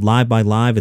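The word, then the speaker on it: LiveXLive: The app is called Live by Live. It's spelled Live X Live.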